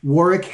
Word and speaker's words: Warwick: And as Glenn understands, as well as Warwick, Warwick has a silent W in the middle. So on LinkedIn, it's at W-A-R-W-I-C-K Fairfax.